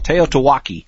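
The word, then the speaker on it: TEOTWAWKI: it's called Teotihuacan. At least that's how we pronounce the acronym.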